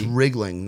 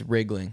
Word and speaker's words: Riegling: It's Rig like a truck and then Ling like... – Anything. – Like anything. Like Ring. Like Ring-a-Ling, you know? It's like Riegling. Riesling like the wine, but with a G.